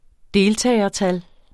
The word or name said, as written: deltagertal